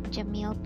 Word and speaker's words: Jamil: It's my full name, Shazia Jamil Pirizada. Shazia is spelled S-H-A-Z-I-A, Jamil's J-A-M-I-L, and Pirizada is P-I-R-Z-A-D-A.